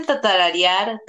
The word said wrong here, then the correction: tararear: tatarareala. – ¿Tararear?